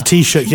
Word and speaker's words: Taoiseach: It's the Taoiseach, yeah.